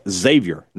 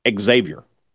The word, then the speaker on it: Xavier: So Xavier, make sure you tell your friends it's Xavier, not Xavier. As a guy who went to St. Francis Xavier Academy in Vicksburg, Mississippi. There's no X. The pronunciation of the X is not there. It's Xavier. There's a Z on the front.